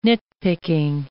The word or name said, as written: nit-picking